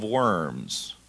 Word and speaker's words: Worms: What he got was something called a diet of worms. Not really. There is a place called Worms. When you see a German W, you pronounce it like a V, so it's spelled like worm.